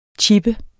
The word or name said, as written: chippe